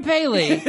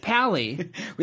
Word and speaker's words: Pally: And also, we love Adam Paley. – but no, we love him. – Pally.